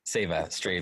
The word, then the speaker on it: Seva Stray: Did I say that correctly? – Yes, yeah, Seva. – Save a stray. – Play on the word like save a dog.